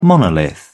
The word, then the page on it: monolith: British English